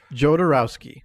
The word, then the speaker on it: Jodorowsky: Jodorowsky.